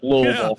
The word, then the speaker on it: Louisville: If you're from here, though, you pretty much drop the I and the S part of it, though, in the Louis. So it's like Louisville. – Yeah. Louisville.